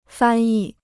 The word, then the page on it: 翻译: fān yì